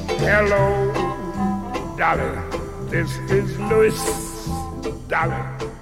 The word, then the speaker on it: Louis: He prefers his name to be pronounced Louis. – This is Louis.